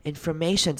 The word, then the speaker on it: information: Information 这个字要特别注意，也是很常念错的，是 OR，中间的 OR 要念成 ER 的声音， information， information，不会念成 information，所以注意要注意到这个音 ER 的声音很常出现， information。